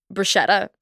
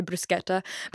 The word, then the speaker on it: bruschetta: I always just say it in my accent. Like I say it how I would pronounce it. But then like it does sound really stupid when you say it in front of an Italian person. An Italian person was like, that's not how you say it. You should say bruschetta.